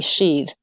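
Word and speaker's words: sídhe: And in early Irish sources, it was referred to as the realm of the sheath, and that's spelled S, I with an accent, D, sheath.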